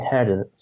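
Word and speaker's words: Huerter: He can become a Kevin Herder. Am I pronouncing it right? – Yeah.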